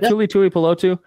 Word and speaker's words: Tuli Tuipulotu: Tuli Tuipulotu. I think I'm saying that right. Yep. Tuli Tuipulotu.